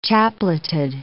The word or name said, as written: chapleted